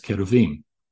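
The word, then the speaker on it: keruvim (כרובים): These Lamassu are one and the same as the Hebrew cherubim, correctly pronounced as keruvim.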